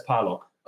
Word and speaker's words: Parlog: So my family says Parlog. – Okay. – And so in English, I'm Parlog. Like even I say Parlog when I say my name in English. It's kind of weird.